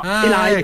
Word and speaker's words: Eliza: Mi piaceva quando gli americani mi chiamano Eliza. – Ecco, è la pronuncia, diciamo, americana, sì, di Eliza.